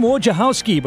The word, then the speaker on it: Wojciechowski: He pronounces his name Wojciechowski, but the new Marquette basketball players are already calling him Coach Wojo.